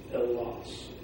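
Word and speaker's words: angelos (ἄγγελος): When that happens, the first gamma is pronounced like an N. That's how you get the mm sound. So, example would be angelos. G would be if you were sending it out, but because of the gamma nasal, you go angelos.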